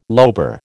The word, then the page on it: lobar: American English